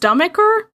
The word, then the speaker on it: stomacher: I'm going to say that's pronounced stomacher? Stomacher. Stomacher? Yeah. That sounds right.